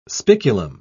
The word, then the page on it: spiculum: British English